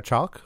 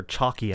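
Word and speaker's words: Chalke: JD once again wrestles with his feelings for fellow resident Elliot, who's played by Sarah Chalk, who was the replacement Becky on Roseanne. – Is it Chalk or Chalky? – It is Chalk. She's pronounced it as Chalk.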